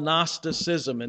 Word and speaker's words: Gnosticism: They taught something called Gnosticism, and there's a silent G at the beginning of that word.